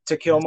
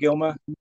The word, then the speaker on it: Takelma: uh, Takelma. Sometimes it's pronounced with a D, but uh, Takelma or Doug Dougilma.